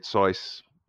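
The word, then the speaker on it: Saïss: So for example, I'm going to bring out the fact that their player that got red-carded, Sice, that's how you pronounce his name, the two yellow cards, both of them were Ward and Zaha working together, breaking, and him having to make tactical fouls.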